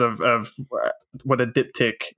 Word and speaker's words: diptych: Diptych.